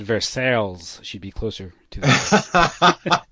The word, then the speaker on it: Versailles: It's Versailles, it's French.